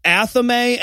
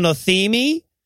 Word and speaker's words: athame: yeah, no, and he's like, eh, uh, oh, also, again he, he, talks about the knife that the Wiccans use, which is pronounced correctly pronounced athame, and he calls it an athemi.